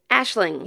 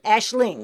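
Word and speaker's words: Aisling: I wanna tell everybody because Ashlyn, is that how you say her name? – Ashling. – Yeah, Ashling. Mm-hmm. Or Ashlyn. Ashlyn. – But Doyle. – It's A-I-S-L-I-N-G. Yeah. Right. – Yeah. Ashling.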